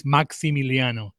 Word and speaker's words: Maximiliano: We can do it in Spanish. It's Maximiliano.